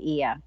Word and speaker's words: Oia: They had been invited dinner to his villa to watch the sunset in Oia. Is that how you pronounce it? – Ia. – Okay. – Ia. Oia is how they said it on the audiobook. But I believe the locals say Ia.